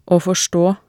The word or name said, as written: aa forstaa